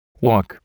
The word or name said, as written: walk